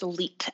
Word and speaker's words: Leet: So A-L-L-Y-S-O-N. And my last name is Leet, L-E-E-T.